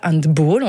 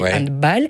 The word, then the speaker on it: handball: Prononcé comme le petit bal du samedi soir. On ne dit pas handball, on dit handball.